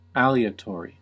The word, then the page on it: aleatory: American English